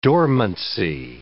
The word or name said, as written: dormancy